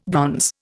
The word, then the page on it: bronze: Indian